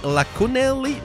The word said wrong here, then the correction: Iaconelli: mais c'est un I au début. Et un I majuscule puis un L, ça se mélange, alors c'est Danny Iaconelli. Iaconelli.